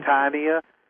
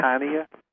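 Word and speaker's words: Houttuynia: To spell that Houtainia because we're not you. – Houtainia. – Yeah. – Houtainia.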